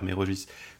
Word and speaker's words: Méraugis: Oui, c'est ce qui est mentionné dans les éditions, notamment dans l'édition de Mérogis de Port-Légué ou Mérogis, je sais pas, tu prononces Mérogis, moi je dis Mérogis, Méro, c'est comme GIF.